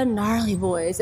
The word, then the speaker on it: Gnarl3yBoyz: So I'm gonna jump right into it the gnarly boys.